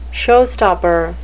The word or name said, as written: showstopper